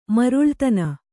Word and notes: ಮರುಳುತನ: maruḷutana

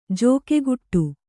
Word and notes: ಜೋಕೆಗುಟ್ಟು: jōkeguṭṭu

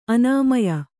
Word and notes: ಅನಮ್ಯ: anamya

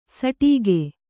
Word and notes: ಸಟೀಗೆ: saṭīge

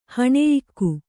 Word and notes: ಹಣೆಯಿಕ್ಕು: haṇeyikku

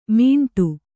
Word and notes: ಮಿಂಡು: miṇḍu

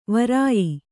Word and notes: ವ್ರಯ: Kannada

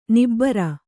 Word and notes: ನಿಬ್ಬರ: nibbara

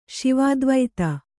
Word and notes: ಶಿವಾದ್ವೈತ: śivādvaita

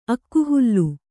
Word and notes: ಅಕ್ಕಿಹುಲ್ಲು: akkihullu

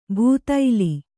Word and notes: ಭೂತೈಲಿ: bhū taili

